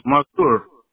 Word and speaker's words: Mazur: How do you spell that? – M-A-Z-U-R. – Got it. Mavur, right? M-A-V-U-R? – M-A-Z-U-R. – Got it. Z. Okay. Sorry about that. I did a V like Victor. So Z like zebra. Got it. Mazur.